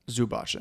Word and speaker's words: Zubac: For majority of the time, though, Clippers do have Zubac in.